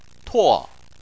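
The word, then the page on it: tuo: tuo4; tone 4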